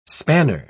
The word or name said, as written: spanner